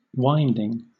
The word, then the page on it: winding: British English